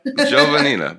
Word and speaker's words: Giovannina: Giovannina? – Giovannina. You know, those Italian names, there's a lot of syllables, and everybody tries to pronounce each one. – But you could kind of smooth it out.